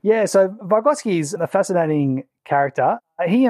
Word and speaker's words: Vygotsky: And then the person that you focused on was Lev Vygotsky. Is that how you pronounce it? – That's correct. Yep.